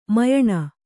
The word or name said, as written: ಮ್ಯಾನಾ